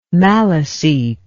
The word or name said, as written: maliseet